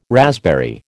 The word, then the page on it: raspberry: American English